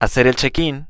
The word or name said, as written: hacer el checkin